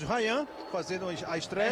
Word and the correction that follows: Rayan: É Rian, viu, Rian. Rian mesmo. Ok. É porque é com Y, né? Eu aprendi que era diferente as pronúncias, né?